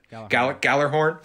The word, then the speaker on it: Gjallarhorn: Meanwhile, her dad is meeting with some people at this organization called Gjallarhorn. Now, I think that's how it's spelled, or Gjallarhorn. That's how it's said. Gjallarhorn.